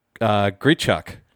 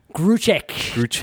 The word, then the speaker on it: Grichuk: Gruchek. – Damo. Okay. – Didn't you learn to pronounce things phonetically? – Well, if I did pronounce it phonetically, you smart ass. It's a U and not an E.